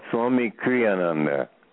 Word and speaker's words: Kriyananda: And we've been talking with Swami Kirananda. Is that how you say your last name? – Well, thank you for asking. You got it pretty close, but it's Swami Kriyananda. – Kriyananda. Thank you.